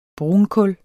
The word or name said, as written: brunkul